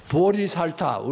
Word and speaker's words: 보리살타: 보살이라는 건 뭐냐면, 보디사트바, 보리살타, 우리말로 하면 보리살타라고 읽습니다만은, 깨치기는 깨쳤대.